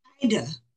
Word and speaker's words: Ida: Ida. – Ida. Oh, sorry. I'm really sorry. Okay. – Ida, sweet as apple cider.